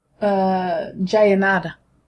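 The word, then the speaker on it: Jaenada: There's a guy called Oscar Jayaneda. Is that how you say it? – Jayaneda.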